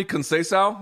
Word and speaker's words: Conceição: He goes, pronounce his name for me, Conceição.